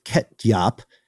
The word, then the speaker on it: ketjap: In the Netherlands, there's a sauce called ketjap, K-E-T-J-A-P. It's an Indonesian soy sauce, but when you pronounce the names, very similar to ketchup.